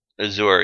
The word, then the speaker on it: Azure: Is that how you pronounce that? – Azure, yeah, that's how I would say it.